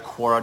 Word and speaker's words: Quora: And yeah, Q-U-O-R-A. And yes, that is correct, Quora. Just Google Quora, Q-U-O-R-A. I think that's how you pronounce it. That's how my brain tells me to pronounce it.